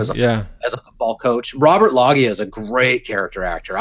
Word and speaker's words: Loggia: I was thinking of, I don't know how to pronounce his last name, Robert Loggia, Loggia.